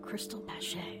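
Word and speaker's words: cristal Baschet: So you've made an instrument recently that is really unique, and I'm going to try to say it correctly, crystal bache.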